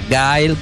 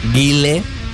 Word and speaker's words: Guile: También está Gail, que es el um, Guile para aquellos que no, sabemos pronunciar en inglés, que es el militar.